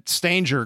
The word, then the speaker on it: Stanger: That was my parents had always said Stanger growing up, and but anybody I would meet would always just default to Stanger if they didn't know the pronunciation.